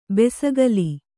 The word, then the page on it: ಬೆಸಗಲಿ: besagali